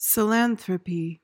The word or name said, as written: psilanthropy